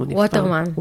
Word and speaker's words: ווטרמן: ממש, אני זוכר כזה בתערוכה של ליאור uh, אה, וטרמן, ווטרמן או וטרמן, איך מבטאים? – ווטרמן. ווטרמן.